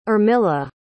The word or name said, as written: armilla